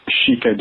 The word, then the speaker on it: shika: By the way, as someone who speaks Japanese, I'll set the record straight and inform you that it's pronounced Shika Deer, not Sika Deer.